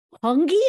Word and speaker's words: hongi: Please tell us about the, I don't even know if I'm going to pronounce it right, but the divine hongi.